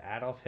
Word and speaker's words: Adolf: All right, well, not to throw a wrench in it, but my number four is Adolf Hitler. – Oh, I knew it. – We all knew you were going to say that. – And he even pronounced it correctly.